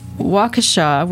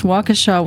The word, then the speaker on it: Waukesha: and this comes from Waukesha, we think that's how it's pronounced, Waukesha, Wisconsin, or Waukesha, Wisconsin. A